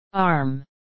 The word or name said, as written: arm